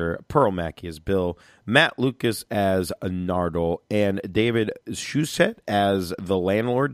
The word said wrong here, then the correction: Suchet: Did I say that right? – Suchet. – Like sachet. Like sachet. – Like sure, why not? – But Suchet.